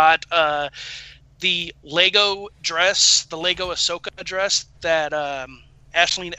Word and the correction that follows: Ashley: Eckstein – did I pronounce her name right? – Ashley Eckstein, yeah.